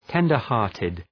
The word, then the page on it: tender-hearted: ‘tendər,hɑ:rtıd